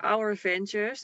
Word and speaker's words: Ourvantures: Nou, ze kunnen ons vinden op Instagram onder Our Ventures. Dus Our als in ons. En Van van de, de camper van.